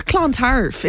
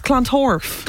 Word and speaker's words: Clontarf: It's not Clontarf, it's Clontorf.